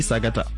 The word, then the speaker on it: Sagata: On va écouter donc le titre Sagata, qui s'écrit Sagata. – Sagata, oui. Ouais. C'est Sagata, ça veut dire. – Ok. – Ça se prononce aussi Sagata.